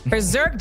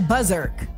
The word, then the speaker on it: berserk: B-E-R. – Oh, berserk. – B-E-R-S-E-R-K. – Oh, word. Okay. – Berserk, generally, I've always said buzzerk.